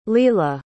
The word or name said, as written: lela